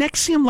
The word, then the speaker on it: NXIVM: this NXIVM self help idea thing was Isn't Nexium like a cholesterol medication or a well it's spelled nxivm oh, ah. – but it's pronounced nexium that's fancy um and this dude keith rainier Raniere, uh, also known as the vanguard if you're in NXIVM Wow.